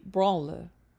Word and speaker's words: branle: Typical dances of the time included the pavin, galliard, and the branle. Branle is B-R-A-N-L-E.